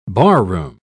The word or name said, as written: barroom